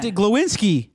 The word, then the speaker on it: Glowinski: Did I say it right or not? – Glowinski. – That's what I said.